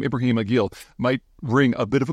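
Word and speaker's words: Aqil: A-Q-I-L. I think it's pronounced Aguil, like a chew, but Aguil.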